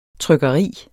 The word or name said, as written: trykkeri